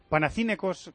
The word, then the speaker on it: Panathinaikos: Panathinaikos, que dice Pedro Martín que se dice así, yo. – Si lo dice Pedro. – Panathinaikos Ajax.